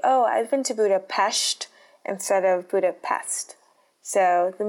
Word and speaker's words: Budapest: I'm saying Budapest because that's actually how you say what we Americans might call Budapest. Because in the Hungarian language, you don't pronounce an S as unless it's next to a letter that they call Z. So if the S is next to a Z, then it would be pronounced Budapest. But because there's no Z in Budapest, you actually pronounce it Budapest.